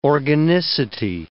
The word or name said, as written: organicity